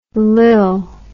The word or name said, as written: lil